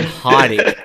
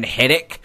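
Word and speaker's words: Heidik: He's he did a video this week of like top 10 survivors who have gone to jail, and he talks about Brian Heidick, and he pronounced it like Brian Heddick or something like that.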